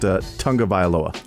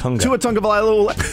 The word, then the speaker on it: Tagovailoa: By the way, Tua explaining his name is pronounced Tagovailoa. Tua Tagovailoa.